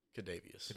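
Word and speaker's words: Kadarius: Brown, Kardavius Toney, and Sky Moore all to score touchdowns. – Kadavius. – How do you say it? – Kadavius. – Kadavius Toney.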